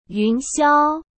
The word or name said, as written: yunxiao